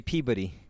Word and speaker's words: Peabody: If you were from Boston, you'd say Peabody. – Peabody. – That's how they say it in there.